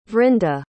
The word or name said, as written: Vrinda